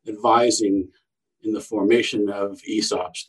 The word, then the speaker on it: ESOPs: That's how it's pronounced, ESOPs.